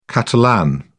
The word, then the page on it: catalan: British English